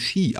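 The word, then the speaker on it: Sídhe: Es wird irgendwie Sitter geschrieben, aber es wird einfach nur Shi ausgesprochen.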